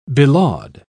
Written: belaud

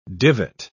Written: divot

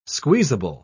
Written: squeezable

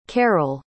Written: carrol

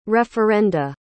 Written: referenda

